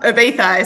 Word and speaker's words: Ibiza: Depends if you're Hispanic or not. Ibiza is how they say it. – I'll see. I didn't say it with the TH.